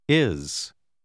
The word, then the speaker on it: is: Generalmente la S finale si pronuncia z. – Is. Is.